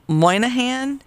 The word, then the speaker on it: Moynihan: All right, comedian Bobby Moynihan. Did I say that right? – Yeah, you did.